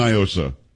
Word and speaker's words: Niosa: Do not, because it's definitely Niosa. It is not a Spanish word. What does Niosa mean in Spanish? Nothing. – So, I don't know. – But that's the whole argument. They try to pronounce it like it's Spanish. It's not. It's an acronym. It's, and for those around the country, it's Night in Old San Antonio, and here we've always said Niosa, and for some reason, like the Joaquin Castros of the world think you've got to pronounce it like it's a Spanish word.